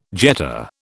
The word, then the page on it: jet: British English